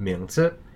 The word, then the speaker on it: 名字: Next up, we have the word 名字. So that means given name, and it's spelled M-I-N-G in the second tone and Z-I in the neutral tone or no tone.